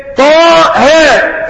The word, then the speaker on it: طه: لكن انت تقرأش كده اي حرف اخر الهمزة ما بتنطقش ومفهوش مد فبتقول ايه طاها.